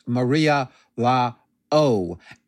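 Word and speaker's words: María la O: I know my Spanish is terrible, but I believe that's pronounced Maria La O.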